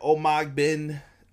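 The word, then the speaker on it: Omogbehin: No, that was Jordan Omogbin. I think is how you say it. Omogbin. He's the tall, newest recruit down in the NXT Performance Center. I'm going to give kudos to Rob for telling me how to say that name.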